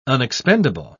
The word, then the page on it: unexpendable: British English